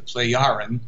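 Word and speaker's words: Plejaren: These are conversations between Meyer and the people who are actually called the Pleiaren, the J being pronounced like a Y, the Pleiaren.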